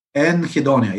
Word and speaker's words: anhedonia: The other article I have pulled up is serotonin causes, how do you say that, anhedonia and mental illness in general? – Anhedonia, yes. Anhedonia.